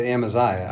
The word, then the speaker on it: Amaziah: How did you say this name? Am- Amazia? Uh, you say it Amaziah. I do, and, but it doesn't matter to me. – Yeah, so it's Amaziah.